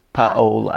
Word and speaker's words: Paola: It's like saying hola, but you put the pa. – Paola.